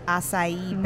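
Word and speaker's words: açaí: I am drinking a ginger acai berry martini. – Wow. – Is that how fancy. – You pronounce it? Acai. – That's what I was told. – Yes, well, it is.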